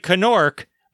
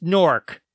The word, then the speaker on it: Knork: It's a silent K. It looks like Knork, but it's pronounced Nork.